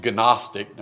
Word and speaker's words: Gnostic: Gnosis is G-N-O-S-I-S. Gnosis. And of course, you can see Gnostic. Now, we say Gnostic, we make the G silent, but the Greeks would pronounce it with the G.